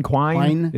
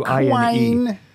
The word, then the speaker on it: Quine: I don't know how to say his last name. – Quine. – It's Quine.